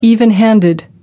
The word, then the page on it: even handed: American English